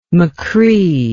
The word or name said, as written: machree